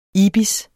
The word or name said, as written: ibis